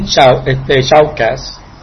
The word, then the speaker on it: Shoutcast: Porque yo sé que hay personas que no tienen shoutcast y están esperando ahora mismo. – Shoutcast. No shoutcast. Es shout.